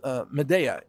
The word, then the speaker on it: Midea: Midea, M-I-D-E-A.